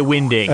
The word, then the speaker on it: Winding: Jazz people were saying, I met Kai Winding, he pronounced it Kai Winding. – I'm sorry. – The name is Danish. So maybe in Denmark it's pronounced Winding.